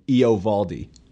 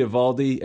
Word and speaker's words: Eovaldi: But Dallas Braden was calling that game, and he was calling him Eovaldi. – Yeah. I don't know. I hate Dallas Braden. So he was pronouncing the O.